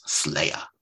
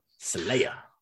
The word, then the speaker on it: Slaya: And that's spelled S-L-A-Y-A. That's Slayer. – Slayer.